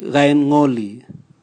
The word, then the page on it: gaingoli: Raga